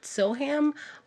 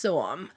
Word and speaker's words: Soham: It's spelled Soham. So people from, you know, the 50 nifty states over here, I would have called it Soham, but it is pronounced Soham.